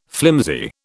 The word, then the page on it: flimsy: British English